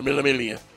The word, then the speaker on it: Mille Miglia: It was finished second in the 1956 Mille Miglia. I better say that right, or my Italian friends aren't going to like me anymore. The Mille Miglia.